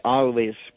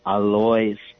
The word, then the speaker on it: Alois: Yeah, well, in German it would be Alois, but over here we pronounce it Alois.